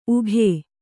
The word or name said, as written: ಉಘೇ